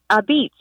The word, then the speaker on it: apizza: a Beats, because that's how they pronounce it in Connecticut.